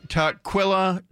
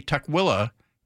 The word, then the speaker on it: Tukwila: Some local reporters say Tukwila. They divide the syllable after the K, and others do the T-U syllable Quilla.